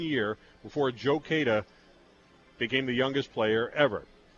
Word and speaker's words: Cada: And by the way, it is pronounced Cada, not Cada.